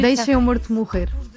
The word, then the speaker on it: Deixa-me Morto Morrer: It's in Portuguese, it's very hard to pronounce Portuguese, but it's almost Portuguese. – No, you Deixa-me Morto Morrer. We eat eats the vowels, so it's very hard.